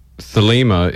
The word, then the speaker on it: Thelema: Thelema, correct, yes. And that is also the correct pronunciation because there's two, Thelema and Thelema.